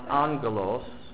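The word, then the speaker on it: angelos (ἄγγελος): In Greek it's pronounced angelos. Almost like Los Angeles.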